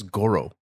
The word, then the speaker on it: Goro: Not spelled the same way, but his name is Goro. It's Goro. – Yeah, it's Goro.